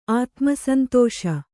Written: ಆತ್ಮಸಂತೋಷ

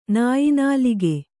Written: ನಾಯಿನಾಲಿಗೆ

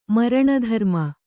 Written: ಮರಣಧರ್ಮ

